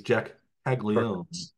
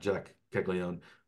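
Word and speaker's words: Caglianone: So there's that second that first N is not pronounced in his name, and the G is pronounced. The hard G sound. Jack Caglione, first baseman and left-handed pitcher.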